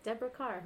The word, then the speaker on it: Deborah Kerr: That was her, like, MGM Welcome to Hollywood campaign that they said, like, Deborah Kerr, her name rhymes with star.